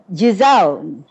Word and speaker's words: Giselle: Hey, French G is pronounced as J. Look Giselle.